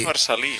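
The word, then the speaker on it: Marcel·lí: Claro, en catalán es Marcellí, en realidad. – Claro. – Pero lleva la L geminada esta, que lleva el punto en medio.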